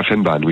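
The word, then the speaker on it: Vennbahn: Et alors, il y a une ancienne ligne, on parlait de la ligne du Van Bamme, c'est ça? – De la Fennbahn, oui, tout à fait. – Ouais, pardon, ça prononce comme ça.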